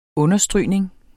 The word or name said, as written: understrygning